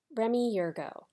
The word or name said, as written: Remi Yergeau